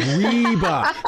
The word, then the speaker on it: Reba: Is that how you say it? And he goes, Reba.